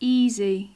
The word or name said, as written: easy